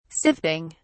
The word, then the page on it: sifting: British English